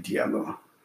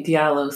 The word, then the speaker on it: Diallo: Because as Africans, D-I-A, we spell it as Ja. And Americans don't spell the Ja, they spell it as Dia. That's why you guys say it's a Diallo and we say Diallo.